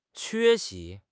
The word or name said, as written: 缺席